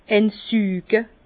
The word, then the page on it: eins süge: Bas Rhin; Herrlisheim